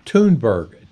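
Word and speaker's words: Thunberg: Her name is pronounced Toonberg. T O O N B E R G, in parentheses.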